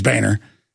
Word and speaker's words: Boehner: It's, look, Boehner and all, I know he says his name's Boehner.